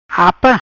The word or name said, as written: Apa